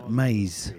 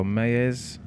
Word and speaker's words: Maes: I'd say it's Maze. – Maze or Mayez? – Yeah. – Something like that. – M-A-E-S. Yeah. Maze. – Look him up. – Mayez? – M-A-E-S.